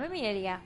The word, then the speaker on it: Amelia: I'm Amelia. – All right. And how do you spell that? – A-M-E-L-I-A.